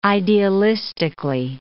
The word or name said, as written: idealistically